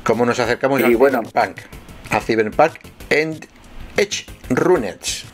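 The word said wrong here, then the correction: Edgerunners: ¿Lo he dicho bien? No lo creo. – Ah, ah. Eh, sí. Eh, eh, Cyberpunk, perdón, o Edge Runners, más o menos. Lo has dicho tú mejor, lo has dicho tú mejor.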